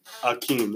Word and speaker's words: Hakeem: No. Pronunciation, Hakeem. The H is silent.